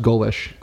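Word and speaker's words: Golesh: He's Alex Golsch. – Yep, I believe it's pronounced Golish. – Golish. – I could be wrong. I believe it's Alex Golish, though.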